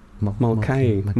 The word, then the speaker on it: Mulcahy: Mulcahy.